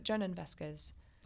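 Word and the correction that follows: Jhonen Vasquez: Oh, Honan Vasquez, yeah. – Honan Vasquez? – Yes.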